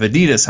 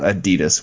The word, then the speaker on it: Adidas: It's actually pronounced Adidas, apparently. – Okay. – Is what she told me when she listened to that. That the company's not Adidas. We always call it Adidas, but it's not.